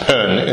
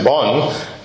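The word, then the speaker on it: Bön: Well, it's pronounced pen in Tibetan, but Western people pronounce it bon, and this is the name of the pre-Buddhist tradition in Tibet.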